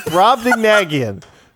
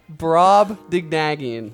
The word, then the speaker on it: Brobdingnagian: It's Brobdingnagian. Okay? That's what you get. – Brobdingnagian.